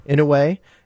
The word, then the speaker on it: Inouye: Inouye.